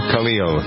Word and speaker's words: Khalil: Thanksgiving Day, 27th of November, the year 2014, entering into the holiday season with the words of Cahill Gibran. Do you ever pronounce the name Khalil? Khalil. Remember him from when I was in college.